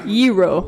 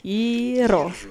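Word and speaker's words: Yero (γύρο): Ye, the G is not pronounced like a G in Greek. Oh. Simply say ye, and then ro. – You do Yero.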